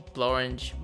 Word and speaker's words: Blorenge: Blorenge rhymes with orange for one.